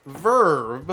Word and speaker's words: VRV: My dumbass did not know this, but it is pronounced Verve. – Oh, I don't think anybody knew that. – Not VRV.